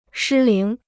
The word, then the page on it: 失灵: shī líng